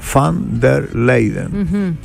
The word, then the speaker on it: von der Leyen: Y van der Leyen, Leiden, es van der Leyen.